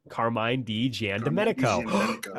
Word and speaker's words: Carmine DiGiandomenico: It's a little more straightforward than Task Force Z, has some of that trademark Rosenberg humor, and is drawn by one Carmine DiGiandomenico.